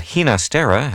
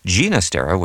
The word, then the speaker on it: Ginastera: I probably should mention that Ginastera has become the pronunciation you hear most often, but Ginastera with a hard G is probably closer to what Alberto heard at home.